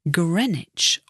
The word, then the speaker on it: Greenwich: Notice the pronunciation there, Greenwich, although it's spelled G-R-E-E-N-W-I-C-H. And an observatory, O-B-S-E-R-V-A-T-O-R-Y, is a place where you can observe.